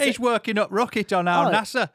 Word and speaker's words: Al Nassr: Al Nassr. How do you say that? Al Nassr. – Al Nassr. – Two S's and an R.